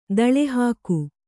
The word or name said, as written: ದಾಳಿಹೋಗು